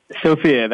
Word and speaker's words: Sofia: And Frank, am I pronouncing your name right, Sofia? – Sofia, that is correct.